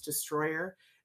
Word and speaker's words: Destroller: It's spelled Destroller, but it's pronounced Destroyer.